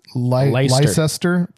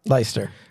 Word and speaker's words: Leicester: L-E-I-C-E-S-T-E-R, but you know that some of those letters... Leicester? – That's how we would say it, but... – Leicester. – It's probably Leicester.